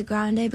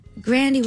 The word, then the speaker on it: Grande: My brother kind of changed it to Grande because, like, Grandy was kind of like the, like, I guess Americanized version of it, you know?